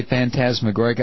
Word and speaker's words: phantasmagoric: Now, see, if I tried to say phantasmagoric, I'd mispronounce it, and I'd get 40 emails saying the accent is on the second syllable.